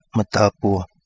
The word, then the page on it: Madabua: Kapingamarangi